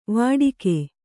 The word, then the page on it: ವಟಕ: vaṭaka